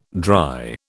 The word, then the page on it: dry: British English